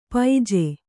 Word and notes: ಪೈಜೆ: kannada

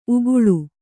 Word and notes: ಉಕ್ಕಲು: ukkalu

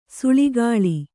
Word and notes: ಸುಳಿಗೊಳ್: suḷigoḷ

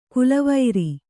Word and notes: ಕುಲವೈರಿ: kulavairi

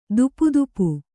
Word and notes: ತೊಪತೊಪ: topa topa

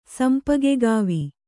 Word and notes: ಸಂಪಗೆಗಾವಿ: sampagegāvi

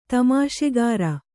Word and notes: ತಮಾಷೆಗಾರ: tamāṣegāra

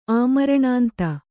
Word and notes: ಆಮರಣಾಂತ: Kannada

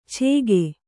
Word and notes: ಛೇಗೆ: Kannada

